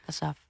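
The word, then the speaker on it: Kassav': Een nummer van Kassaf, zeg ik dat goed? – Ja, Kassaf.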